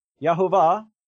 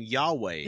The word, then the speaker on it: Yahweh: Jehovah. I don't think that's anyone's correct pronunciation ever. – I've never heard that pronunciation of it before. – That's a terrible pronunciation. I don't even say that because the Tetragrammaton is fucking Yahweh.